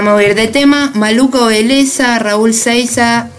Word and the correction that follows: Seixas: es, o no sé cómo se pronuncia. – Seixas. – Seixas.